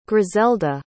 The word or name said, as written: griselda